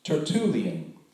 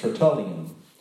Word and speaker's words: Tertullian: This comes from the church father named Tertullian, sometimes pronounced Tertullian.